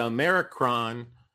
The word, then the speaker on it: Omicron: Like, and and you know the funny thing is, it was like, okay, the Omicron variant has 36 different mutations.